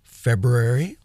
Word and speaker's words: February: We hope to have a full lineup for our second or third week in February. Notice that there's the word brew in the middle of that word, February. I refuse to mispronounce it.